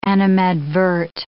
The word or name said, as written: animadvert